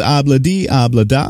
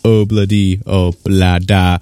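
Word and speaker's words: Ob-La-Di, Ob-La-Da: Before that, the Beatles with Abla D, Abla Da, as we say here in the great northeast of Allentown, Pennsylvania. Everywhere else in the world, pronounce it Obla D, Obla Da.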